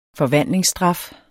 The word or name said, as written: forvandlingsstraf